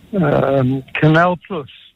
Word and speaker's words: Canal Plus: Canal Plus. – Canal Plus, and even pronounced properly, you get 2 points for that one.